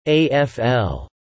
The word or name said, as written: AFL